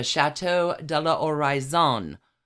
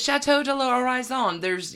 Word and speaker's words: Château de l'Oraison: the Château de l'Oraison. – I can tell already this is going to be a pronunciation bonanza. – It is. Château de l'Oraison. You don't pronounce the H. L'Oraison.